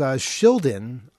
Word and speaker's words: Shildon: Yes, Shildon from level 9.